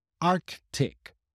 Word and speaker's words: Arctic: Normally, you would hear a lot of native speakers dropping a little bit the Arctic. They would make a little stop, but they would not actually pronounce the K sound very strongly.